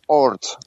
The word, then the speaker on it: Oort: Um, and uh, company name uh, is Oort, like Jan van Oort.